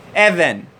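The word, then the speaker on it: Evan (אבן): The stone in Hebrew is called, how do you say a stone in Hebrew? Evan. Three Hebrew letters, Aleph, Bet, and a Nun.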